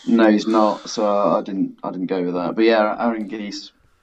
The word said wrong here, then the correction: Anguissa: is that how you say it? – Anguissa, okay.